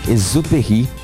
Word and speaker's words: Saint-Exupéry: Falei certo? Antoine Saint-Exupéry. – Exupéry. – Exupéry, perdão, tem um X aqui, é verdade. Exupéry, autor do livro O Pequeno Príncipe.